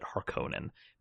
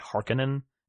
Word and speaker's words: Harkonnen: And I'm also happier than I can express that you pronounce it Harkonnen, because that's how I say it, and too many people say Harkonnen, and I'm like, why would you front load the word like that?